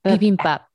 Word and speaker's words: bibimbap: Bibimbap? – Bibimbap.